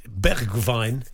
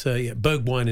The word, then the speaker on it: Bergwijn: And he said it's Bergwijn. It's a bit like Greta Thunberg in Dutch. And he said, but it's Bergwijn in English.